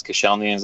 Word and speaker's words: Koscielny: It's Koscielny. Am I saying that right? – Yeah, that sounded good.